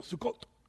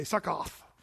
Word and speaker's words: Sukkot: That's how they say it in Israel, Sukkot. You say Sukkoth, but it's really Sukkot, which means tents or booths, tents.